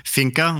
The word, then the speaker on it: Finca: Fianco El Suelo? – Finca, which means farm.